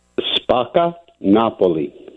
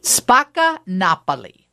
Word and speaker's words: Spacca Napoli: I don't even know how to accurately pronounce the name of your pizzeria, so do it for me. – Spacca, Napoli. – Spacca, Napoli. Because, you know, I always want to say Napoli, but Napoli.